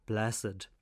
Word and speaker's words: blessed: since canonised but then only blessed, with two syllables.